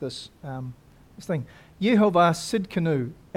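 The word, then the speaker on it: Tsidkenu: And I looked at all the different pronunciations thinking surely it can't be. But it is. It's Sid Canoe.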